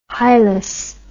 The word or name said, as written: hilus